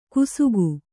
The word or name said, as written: ಕುಸುಕು